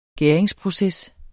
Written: gæringsproces

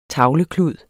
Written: tavleklud

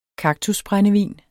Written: kaktusbrændevin

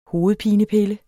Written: hovedpinepille

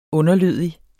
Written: underlødig

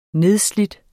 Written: nedslide

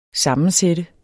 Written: SAMMENSATTE

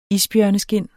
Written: isbjørneskind

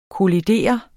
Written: kollidere

